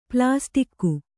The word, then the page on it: ಪ್ಲಾಸ್ಟಿಕ್ಕು: plāstikku; Kannada